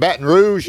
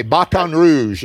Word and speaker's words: Baton Rouge: You're from Baton Rouge. Did I say that right? – You sure did. – Okay, Baton Rouge, as I say.